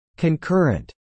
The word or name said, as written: concurrent